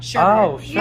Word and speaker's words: sherbet: What is sherbet? – Oh, it's sherbet. – Sherbert. – My family says sherbert. – Sherbert. – Yeah, but have you ever realized that it's spelled sherbet? – Yeah.